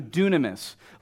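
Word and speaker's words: dunamis (δύναμις): It's actually the Greek word dunamis, D-U-N-M-I-S, or D-U-N-A-M-I-S, excuse me. So dunamis. Look at the person next to you and say it's pronounced dunamis.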